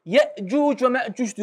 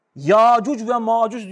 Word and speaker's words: Yecüc ve Mecüc: O kıraate göre Yecüc ve Mecüc diye okunuyor. Ama o yedinin yani bu birin dışında kalan diğer altısı bunlar da mütevatir. Nasıl okurlar biliyor musun Yecüc Mecüc'ü? Yacuc ve Macuc diye okurlar. O yüzden bu okumada son derece sahihtir. Yecüc Mecüc de denilir. Ama Yacuc ve Macuc olarak da okunuyor kardeşler.